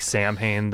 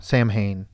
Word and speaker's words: Samhain: What I was gonna say though is that because Danzig had a band called Samhain. And everybody pronounced it Samhain, so they just well, fuck it, we're Samhain. – Yeah. – And ever since then, everyone's just said Samhain.